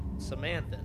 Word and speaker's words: Samanthan: But it's a shortened version of, I'm probably going to butcher it, but Samanthan? – Samanthan, right? – Yeah. – It's like Samantha, but, like, with the male N. The male version.